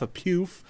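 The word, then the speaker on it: pouf: It's a P-O-U-F, a poof.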